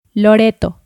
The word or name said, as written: Loreto